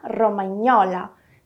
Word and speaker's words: romagnola: Portanto, o GN em italiano será pronunciado como o nosso NH. Outro exemplo que eu posso te dar é o nome de outra comidinha italiana muito gostosa. Piadina romagnola.